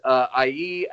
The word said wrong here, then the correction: Saeed: Al Sabah. Or if I even say, I might not have said his name right, or Saeed. How do you say it? – It's Saeed.